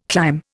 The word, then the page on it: clime: Indian